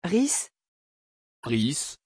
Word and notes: Rhys: French

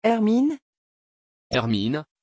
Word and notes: hermine: French

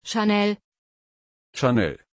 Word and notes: chanel: German

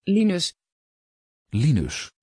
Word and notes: Lynus: Dutch

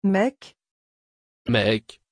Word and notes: Maik: French

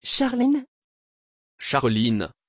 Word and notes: charleen: French

